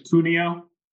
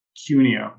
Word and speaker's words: Cuneo: Yeah, last name's Cuneo. – Oh, it actually depends on who you ask in my family. My mom pronounces it Cuneo.